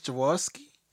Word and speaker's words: Jaworski: Chris Jaworski. – You got it. It's Jaworski or Gaworski, hard or soft G either.